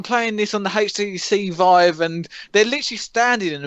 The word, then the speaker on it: Vive: And like the HTC Vive, Vive. Yep. Yeah, I did say that right. I thought it was Vibe for some reason in my brain.